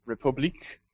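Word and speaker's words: République: So, Republic is, or if we're pronouncing it like the French do, I suppose it would be Republique, is probably the most ambitious mobile game that's happening right now.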